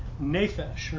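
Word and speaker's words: nephesh (נפש): Well, the Hebrew of that word is this word persons here, and it literally means nephesh, or that's how you pronounce the Hebrew word.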